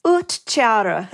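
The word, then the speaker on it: uhtcearu: I'm going to try to pronounce it. Is it Otsiru? – It's Utcharu. – Utcharu. Okay, Utcharu. Well, everyone who speaks Old English right now is yelling at the radio.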